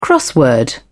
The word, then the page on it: crossword: British English